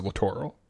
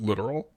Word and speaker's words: littoral: I pronounce it littoral. Some people pronounce it literal.